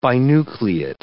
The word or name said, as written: binucleate